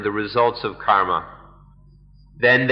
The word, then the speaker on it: karma: But if we, we understand karma, kamma, karma is the Sanskrit pronunciation, kamma, the Pali pronunciation.